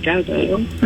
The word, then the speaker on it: Guetta: David Guetta.